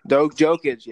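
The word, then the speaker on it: Jokić: Do you think how do you think he's gonna mesh there with Nokic or whatever his name is? I can't say his name. Jokic, yes, the Joker, whatever his name is.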